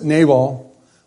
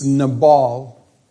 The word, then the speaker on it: Nabal: Now the name of the man was or I guess I've always pronounced it Nabal, but I found out this week it's Nabal.